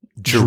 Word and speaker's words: Jrue: and it's spelled J R U. J R U. Yeah. Drew. Drew? – Yep.